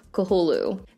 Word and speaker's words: Cthulhu: According to Wikipedia, it is pronounced Cthulhu.